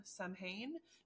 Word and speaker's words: Samhain: Like it is it is spelled S A M H A I N. So I'm sitting here reading it like Samhain. Samhain? No, it's Samhain. There's a W in there.